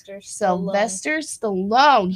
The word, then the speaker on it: Sylvester Stallone: Sylvester Stallone.